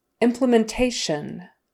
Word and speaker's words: implementation: Implementation, implementation. So stress the second to the last syllable there. Implementation. Again, that T I O N is always S H like U N, shun, shun.